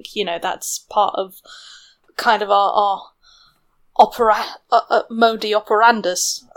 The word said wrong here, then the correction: modus operandi: I'm saying that wrong, aren't I? – modus operandi? – There we go.